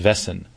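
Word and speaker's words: Väsen: Vassen, V-A-S-E-N, and the album is World, W-H-I-R-L-E-D. This is an incredible album. It's pronounced Vassen.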